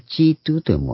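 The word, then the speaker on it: 基督: で、それをイエス・チートゥーと読むわけですけども、イエス・キリストの方。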